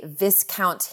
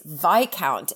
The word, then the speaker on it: Viscount: Why does your credit card say Viscount Hinchingbrook?" And he slightly looked at me in horror and he said, "It's Viscount." And I was like, "But why would it be Viscount? It's spelled exactly like discount."